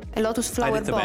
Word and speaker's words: Lotus Flower Bomb: Questa era Lotus Flower Bomb. Ho detto bene, Giovanni? Lotus Flower Bomb, bene, sì.